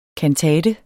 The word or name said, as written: kantate